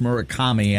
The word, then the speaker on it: Murakami: Yeah, I don't know if his first name is Munetaka, but I believe his last name is pronounced Murakami. – Murakami.